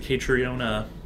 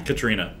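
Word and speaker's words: Caitríona: But no, I mean, I thought is it Catriona? – Katrina. – I'm very like. – Well, it's spelled. That's how it's spelled. – You're Katrina right.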